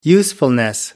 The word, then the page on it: usefulness: American English